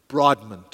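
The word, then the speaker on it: Broadman: Brodmann, two syllables, named after two of the founders of Southern Seminary, godly men, John Broaddus from Virginia and Basil Manley Jr. from Charleston, South Carolina.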